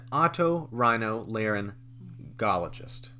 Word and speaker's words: otorhinolaryngologist: So the first one that we have, an otorhinolaryngologist.